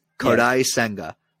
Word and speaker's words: Kodai Senga: Kodai Senga.